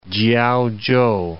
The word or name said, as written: Kiaochow